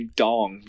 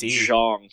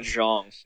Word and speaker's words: Zhong: The two bigs, CJ Walker and I was going to say Dong, but I guess the the announcers pronounce it Zhong. – Yeah, or something. – Zhong. Silent D.